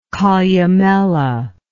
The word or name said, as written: columellate